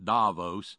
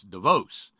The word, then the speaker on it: Davos: It's not Davos. That's how the hillbillies pronounce it. It's Davos. You just like Betsy DeVos. That's how they pronounce the Swiss pronunciation.